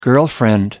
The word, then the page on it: girlfriend: American English